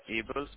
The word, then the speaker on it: Abel: Sure, my name is Abel, spell like A-B-E-L.